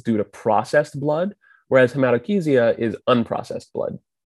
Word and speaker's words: hematochezia: And please, for all of you out there, do not say hematochezia. – I have had attendings who say hematochezia. So it's very bad. Are accepting of all pronunciations, but we prefer hematochezia.